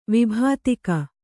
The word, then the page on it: ವಿಭೀತಕ: vibhītaka